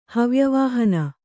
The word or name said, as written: ಹವ್ಯವಾಹನ